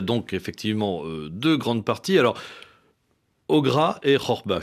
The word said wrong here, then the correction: Gharba: je ne sais pas si je le prononce correctement, mais vous allez me corriger, allez-y. – Ogra ou Gharba. – Bon, Gharba. Voilà, très bien.